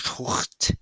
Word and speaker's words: tourte: This cookbook contained a recipe of, tourte is how you say it in French, tourte.